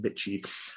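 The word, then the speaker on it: Bitci: Rangers, to my knowledge, have a crypto agreement with a company called, I think it's Bitchy, B-I-T-C-I. I think that's the way you pronounce it, Bitchy.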